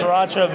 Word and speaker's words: sriracha: Sriracha mayo. How do you spell sriracha? – S-R-I-R-A-C-H-A. – Correct. I always say it wrong. I never say the first R.